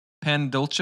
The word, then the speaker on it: pan dulce: Pan dulce.